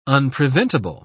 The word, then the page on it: unpreventable: British English